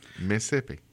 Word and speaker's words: Mississippi: Say it right. Mississippi. You're from there. You ought to be able to pronounce the name of your home state. – Yeah, don't be throwing in an extra syllable.